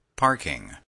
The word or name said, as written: parking